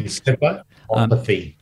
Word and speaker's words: Encephalopathy: Encephalopathy, yeah.